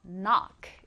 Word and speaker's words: knock: 자, 여러분 들어보면 알겠지만 첫 글자 K는 발음하지 않아요. 무음입니다. 그래서 knock 이렇게 발음해 주시고요.